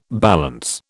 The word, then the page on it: balance: British English